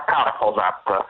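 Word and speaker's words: Kárhozat: Esatto, si pronuncia Karhozat, ecco, con le ultime due vocali nasali addirittura, e il termine può essere tradotto come maledizione o perdizione, e deriva da un film di Béla Tarr, un grande regista ungherese.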